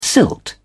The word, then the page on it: silt: British English